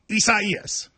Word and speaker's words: Isaias: A lot of people, you know, prayers to everybody involved, but it is Isaias. – Is that how you say it, though? – You do.